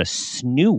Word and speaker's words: Snoo: We talk about kids and all the screens and everything, but just from a parenting perspective, I had no idea what a snoo was. Like, is that how you pronounce it? A snoo? – Snoo, yeah.